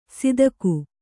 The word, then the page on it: ಸಿದಕು: sidaku